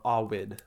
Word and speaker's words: Ovid: And this is from the Metamorphoses of Ovid, or Awid, I guess, in the like more classical Latin pronunciation.